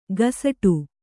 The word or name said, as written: ಗಸಟು